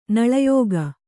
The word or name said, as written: ನಾಲಾಯಖ್